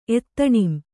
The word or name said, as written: ಎತ್ತಣಿಂ